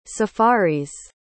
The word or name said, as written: safaris